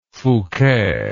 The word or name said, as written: Fouquet